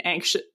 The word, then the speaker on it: ancient: And then all of these definitions said Gothic novels often start with an ancient. I always say that word wrong. Old. It's like the old house. – Ancient. – I'm saying that right? – Say it as a CH. Ancient.